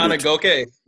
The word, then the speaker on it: Ngakoue: However you pronounce his name. He got traded. – Ngakoue. – Ngakoue? I think it's Ngakoue.